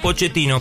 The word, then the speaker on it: Pochettino: Yo te lo pronuncio bien, se pronuncia Pochettino.